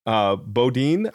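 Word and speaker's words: Bodin: Is it Jean Baudin? How do you say that? – Baudin, I think. – Baudin, okay.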